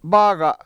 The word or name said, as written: bAAga7